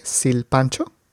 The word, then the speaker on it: silpancho: Y dices silpancho con S de Susana, S-I-L, silpancho. – Sí, lo pronunciaste muy bien.